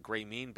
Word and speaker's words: Grameen: And I'm not familiar with, is it Grameen Bank? Is that how you say it in Bangladesh? – Correct.